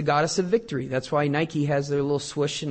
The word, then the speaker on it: Nike: Actually, the pronunciation of the Greek goddess Nike is Nike. It's not Nike. It's Nike.